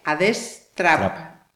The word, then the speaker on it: Adestrap: Mira, eh, hablando también de nuevas tecnologías, hablando por favor, a ver si lo digo bien, ¿eh? Adestrap, como las app, A destrap, Vale, ¿esto qué es?